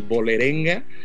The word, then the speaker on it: Vålerenga: Cuando tomas el reto de ir al fútbol nórdico en un país como Noruega, corrígeme si doy mal la pronunciación, el Vålerenga, porque creo que la AL con el... esta cosita la pronuncia como O, te toca ser dirigido por Ronnie.